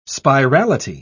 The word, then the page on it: spirality: British English